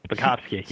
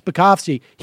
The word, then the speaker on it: Spakovsky: I mean, so guys like Hans von Spassky, I can't pronounce his name, but Spakovsky, yeah. – Yes, Spakovsky.